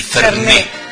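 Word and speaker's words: Fernet: Ay, pero queda tan bien el Ferné. Con acento en la última E, claro. Que es aguda y termina en vocal y acento.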